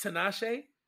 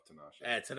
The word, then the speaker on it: Tinashe: Is it pronounced Tinashe? – Yes. – Right? I love her, by the way. – I love Tinashe.